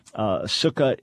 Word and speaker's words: Sukkah: Sukkah is the way it would be pronounced colloquially.